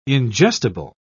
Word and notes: ingestible: British English

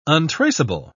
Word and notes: untraceable: British English

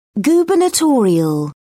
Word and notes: gubernatorial: British English

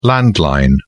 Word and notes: landline: British English

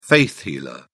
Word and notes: faith healer: British English

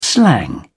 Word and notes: slang: British English

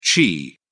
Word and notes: chi: British English

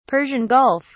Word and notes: Persian Gulf: British English

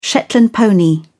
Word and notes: shetland pony: British English

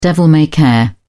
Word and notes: devil-may-care: British English

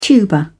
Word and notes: tuber: British English

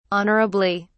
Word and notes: honourably: British English

